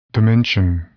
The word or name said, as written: dimension